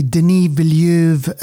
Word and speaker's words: Denis Villeneuve: So Dune is a Denis Villeneuve. I always get that wrong. Deval, please, you said it absolutely right.